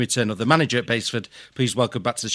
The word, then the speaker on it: Basford: Pleased to find out that you finally pronounced Basford the right way.